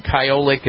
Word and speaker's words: Kyolic: So he would go to the health food store and he would get something called chiolic aged garlic. Chiolic aged garlic. And chiolic is spelled K-Y-O-L-I-C.